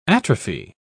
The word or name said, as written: atrophy